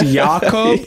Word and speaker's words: Jakob: I'm saying it's pronounced Jakob. – yeah. – It's Jakob. – He's Swedish.